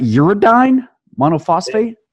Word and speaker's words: uridine monophosphate: Uridine monophosphate? – Yeah, uridine monophosphate.